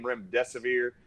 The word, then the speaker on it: Remdesivir: in order to remember the name Remdesivir, just remember this is how you pronounce it. Run, death is near.